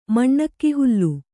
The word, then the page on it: ಮಣ್ಣಕ್ಕಿಹುಲ್ಲು: maṇṇakki hullu